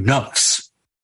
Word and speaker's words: nafs: N-A-F-S. Okay. Or it's actually nafs. – That's it's usually pronounced nafs. It's actually, I think the Arabic is nafs.